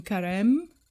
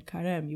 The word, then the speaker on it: Carême: Carême, you wouldn't pronounce that E.